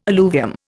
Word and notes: alluvium: Indian

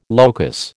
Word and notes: locus: American English

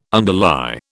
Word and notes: underlie: British English

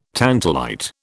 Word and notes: tantalite: British English

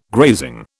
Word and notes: grazing: British English

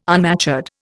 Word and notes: unmatured: Indian

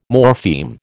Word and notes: morpheme: American English

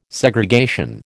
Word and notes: Segregation: American English

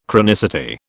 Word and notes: chronicity: British English

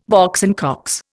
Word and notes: Box and Cox: Indian

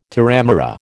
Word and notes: terramara: American English

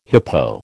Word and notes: hippo: American English

